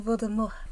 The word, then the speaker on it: Voldemort: Je suis Voldemort. Because you know in the French they don't pronounce the T.